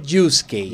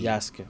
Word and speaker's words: Yasuke: no es Yusuke, Yasuke. Yasuke.